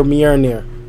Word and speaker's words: Mjolnir: It took me till watching Thor in 2011 to hear Mjolnir. Mjolnir. I still don't even think I'm pronouncing Mjolnir right. – Mjolnir.